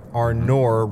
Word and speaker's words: Arnor: Arnor rhymes with door.